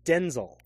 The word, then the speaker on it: Denzel: His name is actually pronounced Denzel.